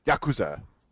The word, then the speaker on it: Yakuza: Yakuza. That's how you say it in Japanese. Yakuza. Sort of.